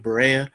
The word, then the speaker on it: Barea: Barea, am I pronouncing his name right? Barea. Back in 2017, Puerto Rico had a hurricane crisis, and Mark Cuban gave his private jet to J.J.